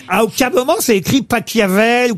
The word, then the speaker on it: Pachelbel: Cannelloni de Pachelbel. – S'il est allemand, ça se dit Pachelbel.